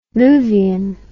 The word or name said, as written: Luvian